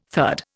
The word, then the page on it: third: Indian